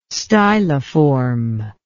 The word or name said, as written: styliform